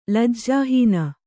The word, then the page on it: ಲಜ್ಜಾಹೀನ: lajjāhīna